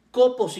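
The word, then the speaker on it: copos (κόπος): La palabra trabajo que se utiliza en el versículo 3 de 1 Tesalonicenses 1 viene del griego copos, con K, copos y con acento en la primer O,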